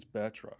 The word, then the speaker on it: Batroc: That's how you say his name, right? Batroc? Or is it Bartroc? – It's Batroc.